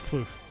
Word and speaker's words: Plouffe: What'd I say? Plouffe? Plouffe? – It's Plouffe. – Plouffe? – Yeah. – He's saying it wrong.